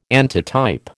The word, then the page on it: antitype: American English